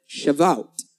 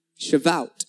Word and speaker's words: Shavuot: They were calling a holiday I'm going to struggle to pronounce in Hebrew called Shavuot. Shavuot.